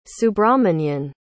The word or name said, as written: subrahmanyan